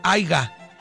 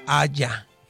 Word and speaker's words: haya: Y no se dice haiga, se dice haya.